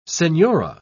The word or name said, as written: senora